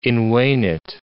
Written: invenit

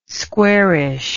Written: squarish

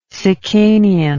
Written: sicanian

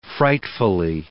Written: frightfully

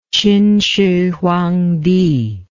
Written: qinshihuangdi

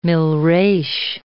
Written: milreis

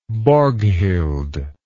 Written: Borghild

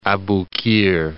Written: Abu Qir

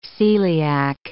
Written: celiac

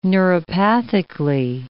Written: neuropathically

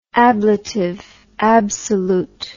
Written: ablative absolute

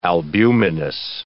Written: albuminous

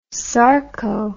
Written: sarco

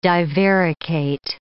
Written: divaricate

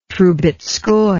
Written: troubetzkoy

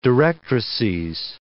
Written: directrices

